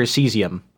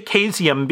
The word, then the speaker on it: caesium: Caesium or cesium? I always heard it was cesium. – I say caesium because... because I understand that C as a hard C.